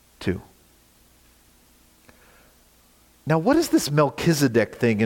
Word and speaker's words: Melchizedek: Good job, by the way, pronouncing Melchizedek. It's a little tricky.